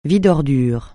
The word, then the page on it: vide-ordures: francais